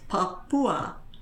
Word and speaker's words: Papua: But see, in Indonesian, that stress comes on next to the last syllable because it's Papua.